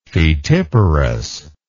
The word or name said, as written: fetiparous